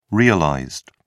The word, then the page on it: realized: British English